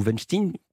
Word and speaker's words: Weinstein: » Évidemment, on pense tout de suite à Harvey Weinstein, ou Weinstein, pour prononcer l'américaine.